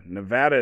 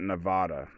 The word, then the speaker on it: Nevada: Nevada is pronounced that, not Nevada.